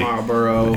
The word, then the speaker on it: Marlboro: That would have been Marlboro. I don't even know how to pronounce that, honestly. – Marlboro.